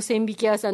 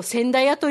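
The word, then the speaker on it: 千疋屋: はいはい、千匹屋さんのことを千駄屋と読むと思ってたっていうことで。でも、引きっていう字別に教えてもらえなかったら本当になかなかわかんないですよね。ね、あれを千匹屋と読むから、漢字を引きって読むんだなって私逆にそれで知ったぐらいなんですけれども、まあ、漢字とかの思い込みっていうか、まあ、間違いって結構あると思うので、なんかそれって、正しい答えを教えてもらった後も、ついつい間違った、思い込んでた方で、言っちゃったり書いたりしません？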